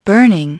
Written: burning

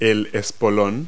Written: spur

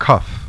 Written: cuff

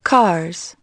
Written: cars

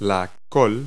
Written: kale

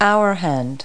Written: hour hand

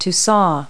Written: saw

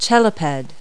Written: cheliped

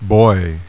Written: buoy

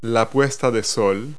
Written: sunset

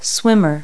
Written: swimmer

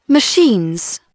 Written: machines

